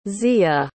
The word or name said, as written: ziya